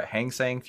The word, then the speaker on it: Hang Seng: So you started trading with the Hang Seng, or did I say that right? Hang Seng Futures. – Yes.